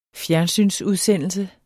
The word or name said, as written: fjernsynsudsendelse